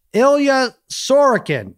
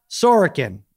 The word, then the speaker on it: Ilya Sorokin: It's Ilya Sorokin, not Sorokin, although it's, it seems like the same thing.